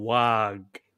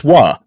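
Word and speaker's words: Waaagh: How many words in the fucking English language have the GH pronounced at the end? It's non, you daft. But it's like, it's Wah.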